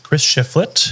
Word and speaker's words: Chris Shiflett: Our other guest is Chris Shiflett.